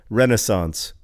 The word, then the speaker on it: Renaissance: And so I looked on How Jay Say, how you pronounce Renaissance, and he says Renaissance or Renaissance. And ever since then, whenever I hear the word Renaissance, I insist on like saying, you know, Renaissance is also an acceptable pronunciation.